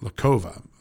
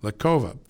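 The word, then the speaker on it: Lokhova: a female by the name of Svetlana Lovka. We've had her on this show several times, and I'm sorry, Svetlana Lakova. Let me say that right. Svetlana Lakova.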